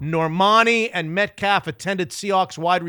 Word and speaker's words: Normani: Normani. – N-O-R-M-A-N-I, confirming her relationship with NFL top wide receiver DK Metcalf.